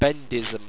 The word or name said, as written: bendism